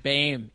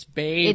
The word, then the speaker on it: Boehm: Boehm. It's Boehm.